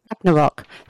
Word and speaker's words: Ragnarok: Yeah, is it Ragnarok? – Yeah. – Ragnarok.